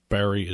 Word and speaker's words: Barre: Uh, by the way, in case you ever see it in print, Barry is spelled B A R R E and there's a hyphen between Wilkes-Barre.